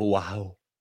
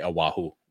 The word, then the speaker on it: Oahu: I am for spring break going to the beautiful island of Oahu. That's not how they say it. They say Oahu.